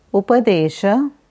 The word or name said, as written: Upadesha